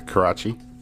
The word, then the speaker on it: Karachi: Am I pronouncing that correctly? – Yeah, Karachi.